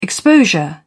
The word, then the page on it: exposure: British English